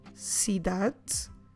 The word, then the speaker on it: cidade: The word for city is cidade. Como se escreve cidade? C-I-D-A-D-E.